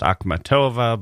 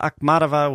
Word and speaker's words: Akhmatova: The Russian poet Anna Akhmatova It's also pronounced Akhmatova, but I'll go with the British pronunciation today. Anna Akhmatova was born in 1889 and started writing poetry at the age of 11.